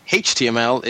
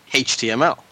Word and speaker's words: HTML: But I know for a fact that in Ireland, we don't pronounce our H's as H's. So for us, HTML is HTML. So it doesn't start with a vowel sound, so we don't put an in front of it, we put an A.